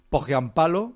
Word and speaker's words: Pohjanpalo: Pohjanpalo, sería un poco la pronunciación finlandesa, aunque en la transmisión directamente salía Pohjanpalo.